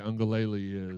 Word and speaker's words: Uiagalelei: And as slow as DJ Uiagalelei is, and uh, yes, I said that right, Higgy.